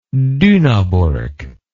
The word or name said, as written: Dunaburg